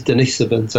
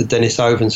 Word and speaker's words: Denisovans: Uh, by the way, that is the correct pronunciation. It is Denisovans as opposed to Denisovans.